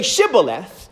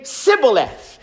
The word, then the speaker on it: Shibboleth: And the Ephraimites could not pronounce the letter H, I guess, because of their accent. And they tried to say Shibboleth, but instead they would say Sibboleth.